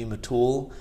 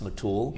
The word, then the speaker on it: Mitul: My parents are very proud of naming me Mitul. And after high school and college, I went by a nickname. There's a few people that were calling me Mitchell because they couldn't really pronounce Mitul.